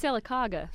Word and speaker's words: Sylacauga: S-Y-L-A-C-A-U-G-A. How do you pronounce that word? Silicaga. – That's Silicaga.